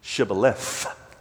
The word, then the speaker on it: shibboleth: And the border guards would kill them because they couldn't pronounce the th, shibboleth.